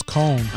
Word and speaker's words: Cohn: Mindy Kahn, how do you say her name? Cone or Kahn? C-O-H-N. – That was Cone.